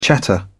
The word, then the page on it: chatter: British English